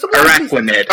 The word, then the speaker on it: Araquanid: And that one is Arakanid. And I hope I pronounced that correctly. But Araquanid. – Araquanid. Araquanid. – Like it's a pun on aqua and arachnid.